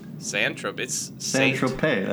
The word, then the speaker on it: Saint-Tropez: For example, Saint Tropez has a... – I think that's Saint Tropez. – Saint-Tropez, that's the brand.